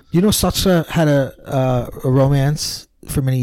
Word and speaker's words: Sartre: How do you say it? – Sartre. – I never know how to say it. – It's Jean-Paul Sartre.